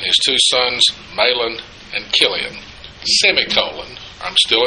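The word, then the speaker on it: Chilion: And one translation uses a K instead of a CH, which gives you a hint of how it's supposed to be pronounced. Kilian.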